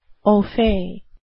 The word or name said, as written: au fait